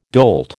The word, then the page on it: dolt: American English